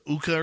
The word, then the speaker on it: Hooka: The Uka or something like that? – H-O-O-K-A. Just the H is silent.